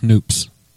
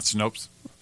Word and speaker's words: Snopes: It's pronounced Snoops. – Oh, not Snopes? – Yeah.